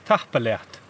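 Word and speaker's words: tapadh leat: Matinva and tahpaleat. – Tahpaleat. – Tahpaleat. Ta. – Ta. – Pa. – Ta. Pa. – Leat. – Leat.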